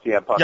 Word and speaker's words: CM Punk: Both Orton and Punk, and I am getting the name right there, right? Like, CM, I'm nervous about CM Punk. – Yeah, you're right. Okay. You've got it.